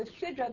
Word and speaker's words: sidra: It's a mispronunciation of the sidra. – Well, it's the sidra. – So, right, but sidra really is a sidra, not a sidra. But listen, either a mispronunciation or, let's say, a dialect.